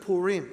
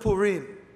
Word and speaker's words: Purim: The Feast of Purim, it's spelled P-U-R-I-M. Most Americans say Purim, but it's Purim. Because if a Jew hears you say Purim, they say, it's Purim.